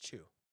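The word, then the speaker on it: qu: Q-U, but it's pronounced chew.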